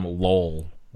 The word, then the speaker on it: Lowell: Okay, where's Lowell? Am I pronouncing it right? Yeah.